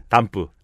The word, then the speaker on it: 덤프: 아저씨들 뭐라고 발음하는지 아세요? 네. 담프. 아 그렇죠. 네. 이 담프 담프 트럭 이렇게. – 그게 좀더 버리는 느낌이 많이 드네요. 네. – 일본어 발음이죠, 일본어.